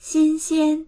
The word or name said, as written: xin1xian1